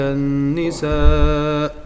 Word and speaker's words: النساء: فحنا نقول الرجال قوامون على النساء بما النساء حرف النون مشدد مكسور.